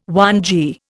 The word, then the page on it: whangee: Indian